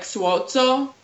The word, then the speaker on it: Suozzo: It's Italian, so in Italy, it's pronounced like Suozzo, but People here don't do that, you know?